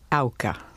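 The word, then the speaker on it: Auca: She became a widow 3 years later when Jim Elliott was speared to death by the Aka Indians. Did I pronounce that correctly, Elizabeth? – Not quite. First syllable rhymes with cow. – Ah. – Auka.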